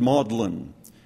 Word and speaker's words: Magdalen: Went to what is spelled Magdalen Hall. I guess they pronounce it Maudlin, and sometimes it was very much so.